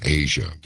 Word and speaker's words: Aja: And it's really funny because given the subject matter tonight, virtually all of these songs, of course, that's Asia by Steely Dan, but it's spelled A-J-A.